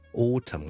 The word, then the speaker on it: autumn: autumn 咧，A U T U M N，大家发觉咧，autumn 我读嘅时候咧系读 tum，M 音尾，而个 N 咧系完全 ignore 咗嘅，完全忽略咗嘅。咁呢个咧其实系一个 silent N 嚟嘅。